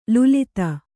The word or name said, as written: ಲುಲಿತ